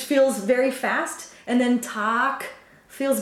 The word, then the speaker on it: Tag: And that Tag, this is one, so one consonant following, and it's a long vowel.